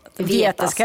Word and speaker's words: Vietas: uttalar jag det rätt mm. nu, Vietas. – Vietas, Vietas, ja, exakt.